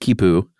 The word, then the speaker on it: Khipu: Is it, I believe it's pronounced Khipu. – Yeah. – K-H-I-P-U. – Or Q-U-I-P-U. – Right. Which would also be pronounced Khipu.